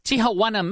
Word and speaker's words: Tijuana: Tijuana, Mexico.